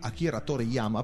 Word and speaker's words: Akira Toriyama: Ok, è un manga che è scritto e illustrato da Akira Toriyama, pronuncia esatta? Io chiedo sempre conferma a te, lei fa di sì con la testa, quindi va benissimo così.